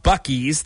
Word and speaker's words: Buc-ee's: There are YouTube videos about all about Buc-ee's.